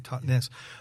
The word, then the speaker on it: Totnes: Yeah, it's the Totnes, as you say it properly, Totnes.